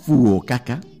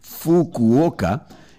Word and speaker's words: Fukuoka: Resulta que en la prefectura de Fukuoka, no lo he dicho mal, ha sonado fatal, pero es que yo no soy japonés. La prefectura de Fukuoka. Ahora sí, Fukuoka, pues la marca Kit Kat, o sea, cuando la pronuncias ahí, es Kito Gato.